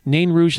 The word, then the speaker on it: Nain Rouge: Nain Rouge is the pronunciation. I've had a hard time with that.